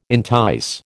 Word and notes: entice: American English

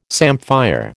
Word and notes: samphire: American English

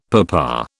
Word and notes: papa: British English

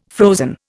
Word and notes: frozen: Indian

